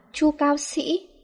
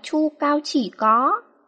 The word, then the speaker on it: Chu Cao Sĩ: Tên cũng phải đọc là Chu Cao Sĩ, có nghĩa là mạnh mẽ, chứ không phải là Chu Cao Chỉ Có.